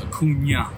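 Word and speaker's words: Acuña: And just so you know, it's Acuna. – Acuna, okay. – Acuna with the little line above the end.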